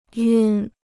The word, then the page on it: 晕: yùn